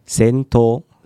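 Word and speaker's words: 銭湯: 那个日本要怎么日语要怎么念？ – 仙托。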